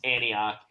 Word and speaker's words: Antioch: And by the way, I know how to pronounce Antioch. I was giving David a hard time because he struggled with it. – Oh, yeah, that's right. – It's Antioch.